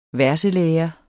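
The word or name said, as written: VERSLÆRE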